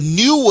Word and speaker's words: nu: Now, the new one makes sense. So the Greek alphabet... after mu comes nu, and it is spelled N-U, but it's pronounced new.